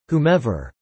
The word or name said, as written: whomever